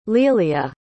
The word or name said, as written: lelia